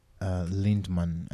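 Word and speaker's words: Lindemann: How do you say the last name? – Lindman. – Lindman. – Think. Lindman. – Okay. – Yeah, I think. – Sorry, it's Lindman.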